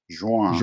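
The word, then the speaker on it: João: Joao, and how did I do in the pronunciation? That's a rough name to pronounce. – Yeah, it's actually in Spanish, it's Juan.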